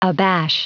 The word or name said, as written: abash